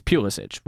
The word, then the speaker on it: Pulisic: Pulisic is how he wants it. – Right, but it's Pulisic when you pronounce it properly.